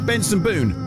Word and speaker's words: Benson Boone: Benson Boone, Rhymes with Spoon.